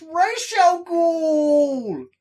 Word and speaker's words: Ra's al Ghul: Seeing it for the first time, I was like, you're saying the name wrong! It's Ra's al Ghul!